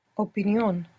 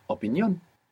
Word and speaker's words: opinión: So, for example, in English we have opinion, and in Spanish you're going to pronounce syllable by syllable, making sure that you're pronouncing the vowels as they're written, and you're going to put the accent on the end.